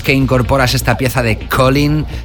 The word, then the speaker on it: Colyn: Se llama Colin. Ha estado sonando muchísimo últimamente, se escribe C-O-L-Y-N.